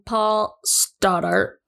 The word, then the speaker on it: Paul Stoddart: So anyways, there was a guy named Paul Stoddart.